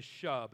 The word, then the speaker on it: shub: The other Hebrew word, which is most common, is the most common Old Testament word for repentance of man, is shub, and it's spelled S-H-U-B.